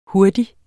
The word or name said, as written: Hurtigst